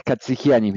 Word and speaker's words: Catsigyanis: Es Katsigianis mi apellido, es difícil de pronunciar.